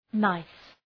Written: nice

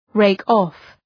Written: rake-off

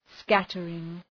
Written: scattering

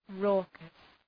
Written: raucous

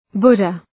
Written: Buddha

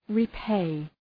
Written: repay

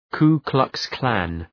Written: Ku Klux Klan

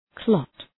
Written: clot

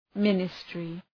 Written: ministry

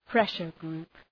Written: pressure group

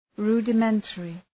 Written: rudimentary

